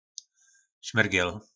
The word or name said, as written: smyrgel